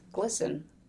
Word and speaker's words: glisten: he says, because I, I wanted to pronounce it right, and he said it's glisten, like listen.